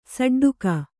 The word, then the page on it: ಷಡ್ಡಕ: ṣaḍḍaka; Kannada